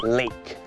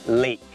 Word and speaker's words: lake: lake ไม่ได้ออกเสียงว่า leg แปลว่าขาแต่คำนี้มันออกเสียงว่า lake